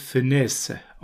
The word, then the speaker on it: Funès: Der Nachname wird Funès ausgesprochen.